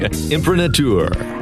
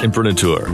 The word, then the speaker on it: imprimatur: Imprimatur. – What? – Imprimatur. Is that right? – No, it's not right. I think it's pronounced imprimatur. – Ooh.